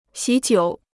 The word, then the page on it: 喜酒: xǐ jiǔ